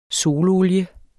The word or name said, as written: sololie